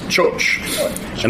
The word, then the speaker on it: txotx: They would they call it choch. It's spelled T X O T X, but it's pronounced choch.